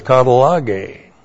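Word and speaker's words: katallagē: The noun is katalage. That's K-A-T-A-L-L-A-G, long E. Katalage.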